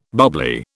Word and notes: bubbly: British English

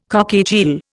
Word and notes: cockatiel: Indian

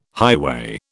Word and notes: highway: British English

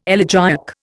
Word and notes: elegiac: Indian

